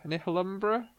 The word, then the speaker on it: Nihilumbra: So I, I finished Nihil- Nihil- Nihilumbra recently. That's how you pronounce it. It's like Annihilation and Umbra put together.